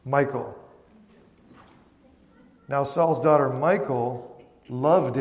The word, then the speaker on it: Michal: Saul's daughter, Michal, or let's talk about pronunciation here, because if you hear English people, especially American, reading this and pronouncing this, we usually pronounce it Michal, even though it's not spelled like Michael. Okay, some might say Mikhail, but actually probably, did some little bit of research on this today, and I'm going to say it to the best I can, but it's Michal, more like that, kind of with that back of your throat kind of sound as you pronounce it.